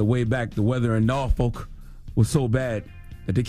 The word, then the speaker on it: Norfolk: They make sure to tell you to pronounce it Norfolk without the R.